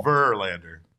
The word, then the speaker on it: Verlander: So the emphasis is on the first syllable. – Verlander.